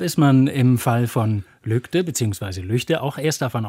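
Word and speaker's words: Lügde: Und wenn man davon ausgeht, dass ein deutscher Ortsname so ausgesprochen wird, wie die gewöhnlichen Ausspracheregeln anzuwenden sind, dann würde man erstmal sagen, der Ort heißt Lügte. – Ja. – Weil das G nach einem Vokal als ein G oder ein K gesprochen wird.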